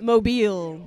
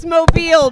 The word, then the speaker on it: Mobile: How do you pronounce it? – Mobile. – Then why the fuck they spell it like that? Show's over. We're done. – It's Mobile because after drinking bleach, you're not very mobile.